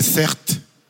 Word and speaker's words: Certes: Ce soir, je vais être transparent sur ma fissure à moi, d'accord Vous avez entendu comment je m'appelle ou pas Je m'appelle Certes. C'est mon prénom. Ça s'écrit C-E-R-T-E.